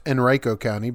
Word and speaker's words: Henrico: Well, if you live in the Henrico County, Virginia area, it's actually pronounced Henrico County, but okay, let's go ahead.